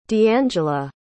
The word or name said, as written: dangela